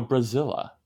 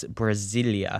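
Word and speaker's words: Brasília: Let's go Brasilia. Well, you didn't say it right but I'll give it to you. It's Brasilia. Brasilia.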